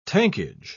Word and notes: tankage: British English

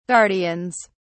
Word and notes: guardians: British English

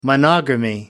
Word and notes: monogamy: American English

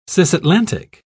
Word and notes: cisatlantic: British English

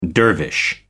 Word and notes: dervish: American English

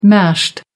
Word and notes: mashed: American English

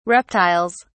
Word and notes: reptiles: British English

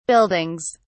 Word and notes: buildings: British English

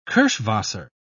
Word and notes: kirschwasser: British English

